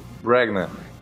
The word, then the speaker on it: Branagh: Kenneth Branagh.